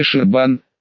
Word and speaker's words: Шербан: Что ж, месье Шербан, с ударением на последнем слоге, ну допусть его, дело в принципе понятное.